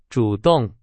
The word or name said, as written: 主动